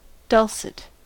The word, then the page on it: dulcet: American English